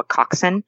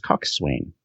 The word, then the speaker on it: coxswain: So for our listeners at home, that's spelled C-O-X-S-W-A-I-N. You might have thought it was coxswain.